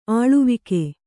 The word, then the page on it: ಆಳ್ವಿಕೆ: āḷvike